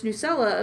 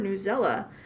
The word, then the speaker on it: Newsela: And we did have a debate on how we should pronounce this as News ELA or Newsela. So we consulted their website to find out. – Kelly, what did you find out? – Okay, so we found out it's Newsela, kind of like Nutella.